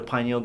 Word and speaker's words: pineal: It stimulates blood flow to the pineal gland. Pineal, phonetically, is very similar similar to penile.